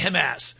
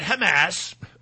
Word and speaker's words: Hamas: I love how the British say Hamas. He was in Gaza, and he was shooting at Hamas. I love that. It's the hard A. The hard A, baby. So I am all for this.